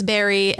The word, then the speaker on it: bury: No, but really, the topic is berry, as in B-U-R-Y.